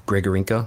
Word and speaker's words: Grigorenko: And I think that we see some problems with that with some of our other guys like Everberg or Grigorenko. Am I saying it right? – Yeah.